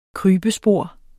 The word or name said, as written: KRYBESPOR